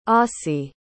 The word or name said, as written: ossie